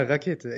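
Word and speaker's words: Rackete: Bei, äh, na, äh, das unterstelle ich jetzt der Carola Rakete die jetzt gesagt Rakete. hat, es müssen. Ja, sie, sie wird doch so ausgesprochen. Ja, ja, Rakete.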